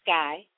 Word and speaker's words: SKAI: well, my name is SKAI. That's spelled S-K-A-I.